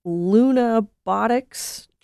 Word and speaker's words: Lunabotics: Steve, I was going to ask you about the NASA Lunabotics Challenge. I think I'm saying that right. Can you tell me about it? – That is correct, and that's what Oliver was talking about.